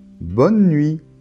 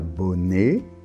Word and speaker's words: bonne nuit: Alors on voit qu'on ne prononce pas la lettre E finale, hein? Bonne, et pas bonnet, bonneux, non, bonne. Et la lettre T finale ne se prononce pas non plus.